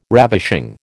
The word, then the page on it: ravishing: American English